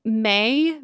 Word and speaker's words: Maille: And then May, the fancy mustard brand, it's spelled M-A-I-L-L-E.